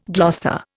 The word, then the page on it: Gloucester: Indian